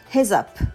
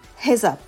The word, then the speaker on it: heads-up: heads up heads up このあストレスですよね強いのは最初の方です he heads head の方ですね heads up heads up になりますそれから、えー、 up は「アップのあですね。